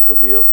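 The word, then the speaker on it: Cavil: You know the name is pronounced different, but spelled the same way, C-A-V-I-L. I say Cavill.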